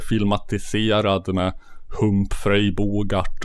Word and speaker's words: Bogart: Uh, det, det var betoningen Bogart. – Ja, Bogart.